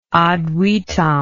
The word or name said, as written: ad vitam